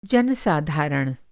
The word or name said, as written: जन-साधारण